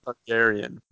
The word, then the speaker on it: Targaryen: The other bet I have, Mary Daenerys how do you say her name? – Targaryen.